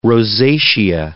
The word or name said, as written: rosacea